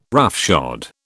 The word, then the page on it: roughshod: British English